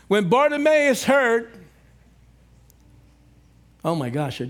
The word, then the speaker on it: Bartimaeus: Bartimaeus.